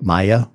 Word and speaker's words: Maya: Maya. – Maya. – M-A-Y-A, yeah.